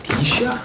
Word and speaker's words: Yisha: Yisha, excuse me. – Yisha. – Yisha. Y-I-S-H-A.